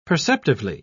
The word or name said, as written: perceptively